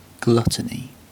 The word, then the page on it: gluttony: British English